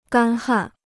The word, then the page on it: 干旱: gān hàn